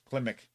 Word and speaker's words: Klimek: Scored by Johnny Kilmick. Kilmick? How do you pronounce that? – Well, if you spelled it right, it says Klimek. – Oh, there you go. Klimek.